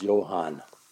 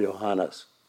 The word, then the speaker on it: Johann: In German, it's pronounced Johann or Johannes, same as Johannes.